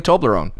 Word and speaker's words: Toblerone: Toblerone. – Don't say it right. Go listen to it. – Right? – Yeah, there you go. Toblerone.